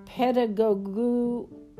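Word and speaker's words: pedagogue: The transliteration of the Greek word, or Greek, would give us our word pedagogu, and I know I could butcher that word, so let me spell it. P-E-D-A-G-O-G-U-E. P-E-D-A-G-O-G-U-E, which literally means a child conductor.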